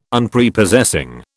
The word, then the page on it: Unprepossessing: British English